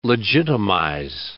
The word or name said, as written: legitimize